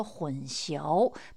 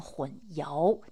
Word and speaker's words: 混淆: 还有一些呢是大众也常念错的字，比方说“ 混淆”，他念成了“ 混淆”，佳肴的“ 肴”。